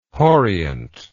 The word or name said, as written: hauriant